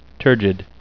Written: turgid

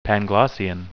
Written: panglossian

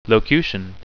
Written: locution

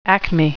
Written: acme

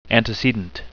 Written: antecedent

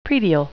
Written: praedial

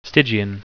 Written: stygian

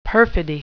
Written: perfidy

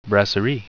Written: brasserie

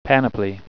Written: panoply

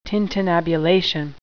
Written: tintinnabulation

